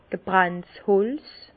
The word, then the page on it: gebrannt Holz: Bas Rhin; Herrlisheim